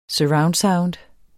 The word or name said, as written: sound